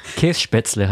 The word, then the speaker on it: Käsespätzle: Das hast du jetzt schon fast ganz richtig ausgesprochen. Käsespätzle heißt es.